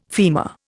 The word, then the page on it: femur: Indian